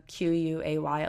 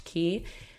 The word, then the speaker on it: Quay: So I really like the Key brand, the Q U A Y. It looks like Quay, but it's pronounced Key.